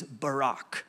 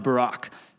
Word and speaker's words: barak: Only that is an incorrect way to say it, because that's our English butchering of Barak. If I say Barak, B-A-R-A-K, you're not getting it. You have to say it like the Hebrews do. You have to say it like you got a little piece of popcorn stuck in the back of your throat, all right?